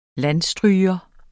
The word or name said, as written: landstryger